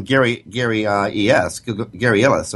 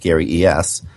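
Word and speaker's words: Illyes: Google, Gary Ellis, or as I understand it's to be pronounced, Gary E.S.,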